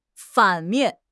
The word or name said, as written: 反面